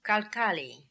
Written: Kalkali

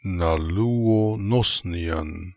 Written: Naloo onosnian

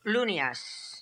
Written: Loonias

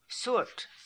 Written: Soort

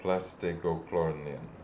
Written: Plastegloklornian

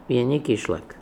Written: Yenikishlak

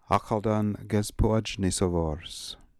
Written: Akhaldangezpoodjnisovors

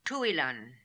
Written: Tooilan

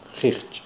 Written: kheeghj

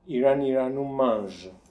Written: Iraniranumange

